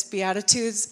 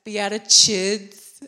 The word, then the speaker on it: Beatitudes: In an American pronunciation, it's Beatitudes. And in Northern Ireland pronunciation, it's Beatitudes.